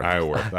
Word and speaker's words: Isleworth: We played Stream Song and then Islesworth. – Yeah. – Isleworth. I keep mispronouncing that.